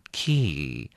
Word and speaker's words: key: 那么 key，哎，我刚刚已经有提醒过各位了，麻烦你在念的时候要稍微把它拉长一下，这样比较像英文，要念成 key。